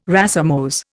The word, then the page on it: racemose: Indian